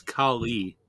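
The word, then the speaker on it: Kali: Honestly, you pronounce it correctly. It's Cali.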